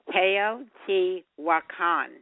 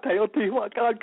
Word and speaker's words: Teotihuacan: Teotihuacan. How's that? – You got it. And by the way, that Teo is from, you know, theology. Yeah, Teotihuacan.